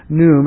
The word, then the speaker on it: Khnum: The god's name was Num, K-H-N-U-M, pronounced Num.